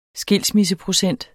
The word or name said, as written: skilsmisseprocent